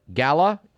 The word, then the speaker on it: gala: The word G-A-L-A, you may pronounce it gala. It's correctly gala. R-E-G-I-M-E, you may pronounce it regime, but the first syllable is supposed to sound as ray, regime.